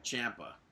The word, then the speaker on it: Ciampa: Wait, what was his last name? – Ciampa. – So the entire CWC and every commentator is saying it incorrectly, even though in a promo he corrected all of them.